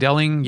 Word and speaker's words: Deling: So then we go to Deling. How are we saying D-E-L-I-N-G? What's our Deling? Deling? Yingling? – Deling. There's one L, so I'm saying Deling.